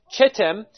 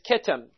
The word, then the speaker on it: Kittim: but the same Hebrew word is translated using a hard CH in Numbers 24:24, where there you would see what most people would pronounce as Chittim, but as well is Kittim.